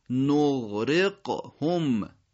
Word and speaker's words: نغرقهم: اولیش غین نغ که تو عربی فارسی یه مقداری با هم فاصله دارن دیگه، اون دوست داره کشیده تر باشه، نغ نغ نغ ادا نمیشه نغ، اون وقت اون قید دوم قافه، نغرق هم نغ نغ نغرقهم.